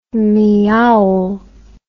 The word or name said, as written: miaul